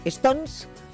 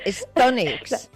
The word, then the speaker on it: StoneX: Se lo vamos a preguntar a Raquel Herrero, que es líder del equipo de operaciones con metales preciosos de Stones Bullion. Raquel, muy buenos días. – Hola, buenos días. – ¿Lo he dicho bien o lo he dicho fatal? Stonex. – Lo has dicho casi.